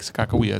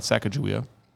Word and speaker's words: Sakakawea: One is Lake Sakakawea. Lake Sakakawea. It's not Sacagawea.